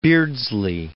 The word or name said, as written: Beardsley